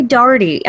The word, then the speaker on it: Daughtry: I'm from Kentucky. I say Darty. I say it like it's Darty? D-A-R-D-Y.